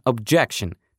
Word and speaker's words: objection: А есть еще и существительное, тоже с ударением на второй слог, как и глагол object, а именно objection.